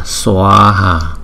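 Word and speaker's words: svāhā: Đó, chữ A có dấu gạch ngang, chúng ta phải đọc nó chậm một chút, chúng ta đọc là Swaha.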